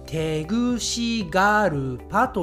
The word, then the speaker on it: テグシガルパ: はい、えー、これはですね、発音は似ているのですが、日本語ではゆっくり言うと、テグシガルパと言います。テグシガルパ。